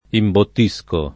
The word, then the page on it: imbottire: italiana